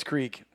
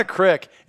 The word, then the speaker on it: creek: So it's creek. It's not a crick. It's spelled C R E E K. It's a creek.